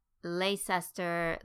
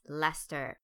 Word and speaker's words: Leicester: I've heard it mispronounced in all kinds of ways. Leicester, Leicester. Hey, I even did it myself years ago. Nope, it's Leicester.